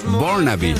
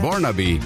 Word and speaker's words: Burnaby: En realidad nació en Burnaby, ahí lo dije bien, Burnaby, Columbia Británica en Canadá, y comparte su vida entre su país natal y Argentina debido a su entorno familiar.